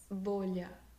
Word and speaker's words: bolha: The word of today is bolha. – Bolha? – Bolha. – Bolha. – Bolha. Bolha. – I'm confused by the first syllable. – Bo, it's B-O, bolha.